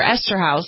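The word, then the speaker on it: Eszterhas: Esterhaus, yep.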